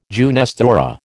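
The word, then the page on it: jeunesse dorée: American English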